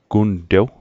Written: guntew

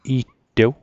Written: i'tew

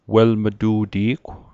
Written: welm'tu'ti'gw

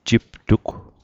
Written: jiptug

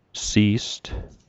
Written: si'st

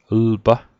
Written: lpa

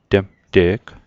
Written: temte'g

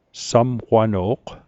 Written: samqwano'q

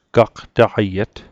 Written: gaqtaqaiet

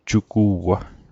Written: jugu'wa

